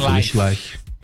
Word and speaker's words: Foolish Life: Como que se pronuncia? Life, né? Foolish Life. – Foolish Life. É isso mesmo, Foolish Life.